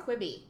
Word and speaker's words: Quibi: It stands for Quick Bites, so I'll call it Quibi. – Quibi.